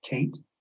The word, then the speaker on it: Tait: Tate. Got to spell it correctly, T-A-I-T, or the nitpickers will disqualify it.